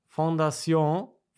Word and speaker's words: Fondation: sie hat erstmal gesagt, Fondation wird französisch ausgesprochen. Und okay, Beiler, das habe ich ja gemacht. Das habe ich ja gemacht. – Beiler also Deutsch. – Also okay, nicht Fondation, so viel Französisch schreibt Beiler.